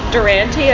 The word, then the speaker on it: Durante: Like, it's an Italian name, so to pronounce it Durante, I feel like it's just such an like a, an American bastardization yeah. of the name,